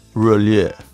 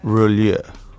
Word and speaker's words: Roulier: It's a French word and it's pronounced Roulier. Roulier.